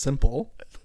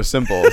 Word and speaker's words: Simple: Sample song number 3 from Ute. – I think it's pronounced Simple. – Oh, Simple. Sorry.